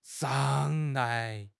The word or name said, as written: 嚷